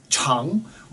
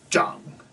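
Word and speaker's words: Chang (长): So the character is Chang, which means uh, ch- long. It's an adjective meaning long. But it can also be pronounced Zhang. Z H A N G third tone or C H A N G second tone. So C H A N G second tone is long, and then Z-H-A-N-G, zhang, third tone, means to grow or to mature.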